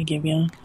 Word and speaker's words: Giveon: Is it Giveon? Is that how you say his name? – Yeah, give you.